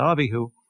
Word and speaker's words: Abihu: And now Aaron's sons, and I'll adopt here the Hebrew pronunciation of their names, Nadav and Abihu, each took his censer, put fire in it, laid incense on it, and offered alien fire.